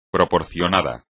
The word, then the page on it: proporcionado: espagnol